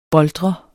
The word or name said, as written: boltre